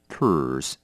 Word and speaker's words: purse: 第一个 purse, 名词，钱包。那么遇到卷舌音的时候，麻烦您要记得了，舌头要往后卷，念起来才会好听，要念成 purse。